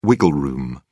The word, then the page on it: wiggle room: British English